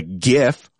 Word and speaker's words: GIF: Comment on that post with a GIF, hard G, GIF,